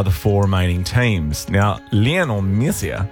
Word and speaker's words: Lionel: Well, apparently I'm a wanker for saying it that way. It's Lionel. – It's Lionel. – Lionel.